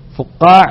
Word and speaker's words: فقاع: وفقاع وهو شراب يتخذ من الشعير، فقاع كرمان، فقاع تنطق كما تنطق كلمة رمان فقاع، وهو شراب يتخذ من الشعير.